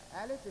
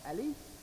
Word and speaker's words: alif: We know the letter alif is called alif. It's called alif, and with emphasis on the a, alif, right?